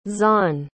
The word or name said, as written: xuan